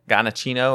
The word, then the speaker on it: Giacchino: Giacchino, or is it? – Your phone's way too far.